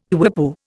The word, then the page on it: quipu: Indian